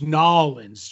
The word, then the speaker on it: New Orleans: It's pronounced Nolens, Joe.